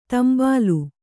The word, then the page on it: ತಾಂಬೇಲು: Kannada